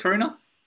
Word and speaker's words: Coruña: Is that how you pronounce it, Coruña? – Coruña.